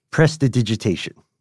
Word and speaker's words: prestidigitation: Prestidigitation. – Man, you nailed that pronunciation, man. That's a tongue twister.